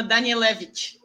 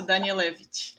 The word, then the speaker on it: Danielevich: Só para tu saberes como é que se pronuncia, se chama Danielevich. Mas é. Da Danielevich. – Isso, Danielevich.